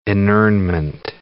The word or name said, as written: inurnment